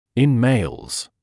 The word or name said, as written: in males